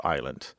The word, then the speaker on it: island: The S is not pronounced in French. Therefore, we don't pronounce it in this word island. So I'm going to recommend a book. It's by Erica Okrent, O-K-R-E-N-T.